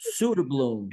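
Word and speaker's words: Söderblom: Is it Soderblom? – Soderblom? Soderblom. – Yes. – Soderblom. Soldering iron.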